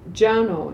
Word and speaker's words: Janeau: His name is Ed Jano, it's spelled like Juno, only J-A-N-E-A-U.